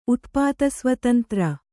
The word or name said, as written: ಉತ್ಪಾತಸ್ವತಂತ್ರ